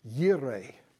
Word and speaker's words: Jireh: And sure enough, once it had all taken place, that's Abraham called the name of that place, Jehovah Jireh. I know some people pronounce it Yireh, but that messes up the song. And I'm not saying which one is right, but Jehovah Jireh for our purposes.